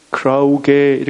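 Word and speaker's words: 크라우게 (κραυγή): 악독, 분냄과 노함, 떠드는 것, 이 떠드는 것이라고 하는 헬라어는 크라우게 이렇게 발음이 되는데요.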